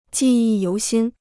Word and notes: 记忆犹新: jì yì yóu xīn

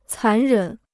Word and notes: 残忍: cán rěn

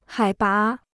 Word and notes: 海拔: hǎi bá

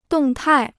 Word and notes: 动态: dòng tài

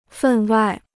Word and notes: 分外: fèn wài

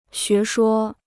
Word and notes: 学说: xué shuō

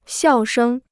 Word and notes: 笑声: xiào shēng

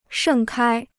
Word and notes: 盛开: shèng kāi

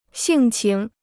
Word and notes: 性情: xìng qíng

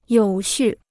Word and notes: 有序: yǒu xù

